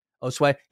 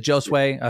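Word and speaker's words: Josué: Osway. Yeah, I've said Josue. Uh, very English. I don't know if they, I don't think they pronounce the J hard.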